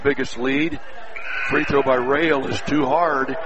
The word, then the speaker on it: Roell: One of two free throws by Rail of Twin Valley South, spelled R-O-E-L-L, pronounced Rail, 6'4 junior, one of two at the stripe.